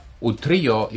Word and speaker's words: Utrillo: Now, you might say, if I would have pronounced it Utrillo, or maybe Utrillo, you might have recognized him, but Utrillo is the right way to pronounce it, since it is a Spanish name.